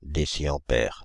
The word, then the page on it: déciampère: Paris; [de.si.ɑ̃.pɛʁ]; France (Île-de-France); /de.si.ɑ̃.pɛʁ/